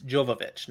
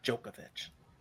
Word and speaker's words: Jovovich: I keep saying her name wrong, by the way. It's Jovovich, not Jokovic.